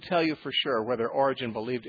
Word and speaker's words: Origen: Have you heard of a theologian, Orion, or I'm not sure how you pronounce it. – Origen. – Origen, yeah.